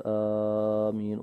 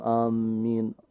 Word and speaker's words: آمين: فالشيخ البعض يمد في أول الهمز يقول آمين، وبعضهم يضعف أو يشدد على الميم آمين.